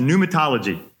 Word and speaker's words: pneumatology: It's pronounced pneumatology, the doctrine of the Holy Spirit.